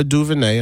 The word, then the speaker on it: DuVernay: He's got a consulting contract for Ava DuVernay.